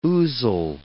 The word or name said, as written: ouzel